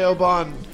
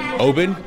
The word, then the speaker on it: Oban: It's not Oban, it's open, like, hey, let's open another bottle so we can keep going? – It's interesting.